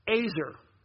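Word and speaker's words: Azer (עזר): In the Hebrew word for helper that's used in Genesis chapter 2 in verse 18 and verse 20 is pronounced Azer. Azer, okay?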